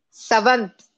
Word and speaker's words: Sawant: You are deliberately mispronouncing her name, right? – Sawant. Sawant. – Shyama Sawant. Yeah. – Sawant. Oh, I think I went all American on her.